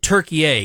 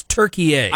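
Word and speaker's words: Türkiye: alternate pronunciation, is it Turkey A? It's not Turkey Yay or Turkey, you know what I'm saying? It's Turkey A.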